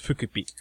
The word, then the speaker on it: Fukuppy: His name is spelled Fukuppy. Yeah, let that sink in. In Japanese, it's pronounced Fukupi.